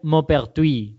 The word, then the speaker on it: Maupertuis: Entonces, verdaderamente, el, nombre en francés de este señor sería Maupertuis, ¿vale?